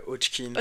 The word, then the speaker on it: Hodgkin: D'un lymphome de Hodgkin, je le prononce bien. – Ouais, Hodgkin.